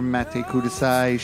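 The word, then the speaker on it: Matte Kudasai: She waits in the air, Mate Kudasai.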